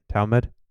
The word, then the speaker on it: Talmud: Talmud? – Mm-hmm.